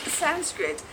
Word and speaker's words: Sanskrit: And it's actually pronounced Sanskrit, and it's the primary sacred language of...